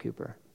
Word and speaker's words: Cowper: If you've ever read about him, his last name is spelled Cowper, but it's pronounced Cooper.